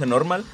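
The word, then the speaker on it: NRMAL: N-R-M-A-L, o Normal. Creo que es Normal, se pronuncia Normal.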